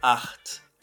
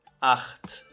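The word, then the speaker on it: acht: Já o 8 é art. Art. Você vai pronunciar aqui com CH na garganta, ou seja, fazendo aquele ronquinho. Art.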